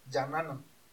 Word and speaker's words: Jahannam: Se pronuncia Jahannam. O en árabe, Jahannam.